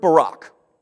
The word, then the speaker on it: barak: So if you look at the Hebrew, the word for bless there, it's the word barak. Sort of like a previous president that we had. It's sort of how it sounds. Barak, okay, different meaning, but same pronunciation.